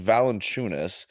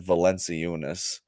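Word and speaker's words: Valančiūnas: It's Valanchunas, but Black Sam's a rapper, so he pronounces everything like it's a handbag. So he said Valanciunas.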